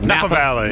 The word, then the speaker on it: Napa Valley: The Intruder is about a young married couple who buys their dream home in Napa Valley. Did I say that right? Napa Valley? – Napa Valley.